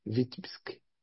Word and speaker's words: Vitebsk: Se escreve Vitebsk, né? Vitebsk, ela é a pronúncia mais aproximada.